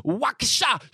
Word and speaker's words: Waukesha: He's seen them up in Waukesha, if that's how you say it. Waukesha Chevrolet.